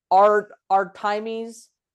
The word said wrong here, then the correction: Artemis: Armides. – Artemis.